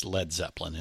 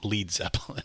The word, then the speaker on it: Led Zeppelin: Uh, the New Yardbirds become uh, Led Zeppelin, uh, and and I, I, I believe in your book Grant makes it the LED, uh, so everybody knows it is Led Zeppelin and not Lead Zeppelin or or That's something.